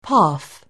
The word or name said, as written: path